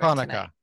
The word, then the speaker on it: Hanukkah: You're mispronouncing that, Julia. – Oh, that doesn't come until the third episode. The third one we're covering. – Hanukkah. – Tonight. Hanukkah.